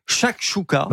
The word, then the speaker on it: chakchouka: Alors ainsi, démoli à la gastronomie, s'y font une place particulière comme, alors je ne sais pas si je vais réussir à le prononcer correctement. – Oula. Allez-y. – Chakchouka. – Bah oui. – La chakchouka, ouais, bien sûr. – Non mais je le prononce bien donc. – Vous le prononcez très bien.